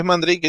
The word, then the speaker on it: Mandrake: Es Mandrake en inglés, ¿cierto? – Sí, es la pronunciación correcta.